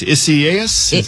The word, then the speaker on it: Isaias: Yes, and the pronunciation we've been talking about is Isaias. I know it is the Spanish pronunciation.